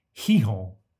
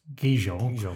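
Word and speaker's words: Gijón: And I was in Gijon, which is how you pronounce what I've previously called Gijon, in Spain.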